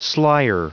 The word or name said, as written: slyer